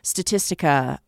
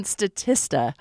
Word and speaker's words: Statista: on Statista. I always say that wrong. On Statista.